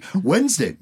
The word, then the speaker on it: Wednesday: Wednesday, you don't pronounce the D in Wednesday, and yet there is one.